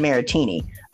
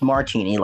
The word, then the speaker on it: Martini: We've been calling him Ryan Maritini, which is honestly what I thought his name was too, but it's actually pronounced Martini, like the drink.